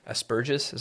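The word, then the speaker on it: asperges: Think of the old tradition of the aspergus, is how you pronounce it, mm-hmm.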